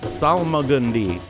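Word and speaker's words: Salmagundi: Salamagundi, I'm sorry, I added a syllable. Salamagundi is at Zalgaz Grotto tomorrow, starting at 8.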